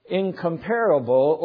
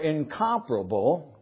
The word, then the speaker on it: incomparable: So what is incomparable or incomparable? Both of those pronunciations are correct. I had to look that up because I used the word. Anyway, they're both correct.